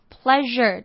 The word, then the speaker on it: pleasure: 那这边要注意 pleasure 跟 pressure 的 sure 呢发音不太一样，愉快 pleasure 的 s 是发 r 的音，那压力 pressure 是发 sh 的音。